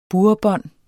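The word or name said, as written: burrebånd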